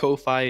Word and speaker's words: Ko-fi: you say coffee, people are going to think it's spelled coffee, you know? – Yeah. I got a coffee right here, guys. – I feel like Ko-fi is the universal Ko-fi.